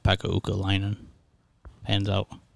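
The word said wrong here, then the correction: Ukko-Pekka Luukkonen: Is that how you say it? – That's not even close. – No, is it? – No. Uka Pekka Luukkonen. – Uka Pekka Luukkonen.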